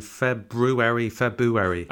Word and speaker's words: February: And we're at the end of February, so it's a kind of a reflection on February. – And February as well. A lot of people just say February and pretend the R isn't there.